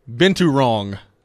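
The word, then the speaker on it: binturong: The first few letters are B-I-N-T. Bintu Rong is the official pronunciation.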